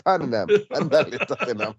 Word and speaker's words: Tottenham: Yo la pronunciación Toneham. – Eh, Tottenham.